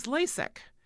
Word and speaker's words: LASEK: Next is LASIK. It's spelled L-A-S-E-K. The other one was I-K. This one, L-A-S-E-K, LASIK, stands for laser epithelial keratomalusis.